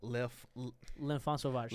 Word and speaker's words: L'Enfant Sauvage: L'Enfant Sauvage.